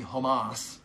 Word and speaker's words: Hamas: The word Hamas, the spelling in the transliteration would be C-H-A-M-A-C, pronounced basically Hamas. I spell it H-A-M-A-S.